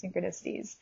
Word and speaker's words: synchronicities: Um, and I had to go back to my friends who had started to adopt the word too, saying synchronosities and be like, hey guys, sorry, it's pronounced synchronicities. And if you read it, it clearly sounds like it would be pronounced synchronicities, but it's just pretty funny spiritual blooper of mine.